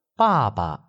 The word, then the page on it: ba4ba0: neutral tone